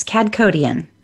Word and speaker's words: Kadkhodaian: It's Kadkhodaian.